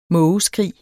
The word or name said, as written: mågeskrig